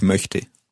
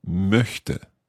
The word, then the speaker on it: möchte: The word would be ich möchte. Ich möchte. That's quite a tricky vowel sound to make there. Let's hear it again. If you can make the vowel sound long so that we can hear it.